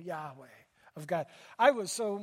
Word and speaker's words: Yahweh: Yod, He, Vav, He. Yahweh is how it's pronounced.